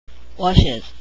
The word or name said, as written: watches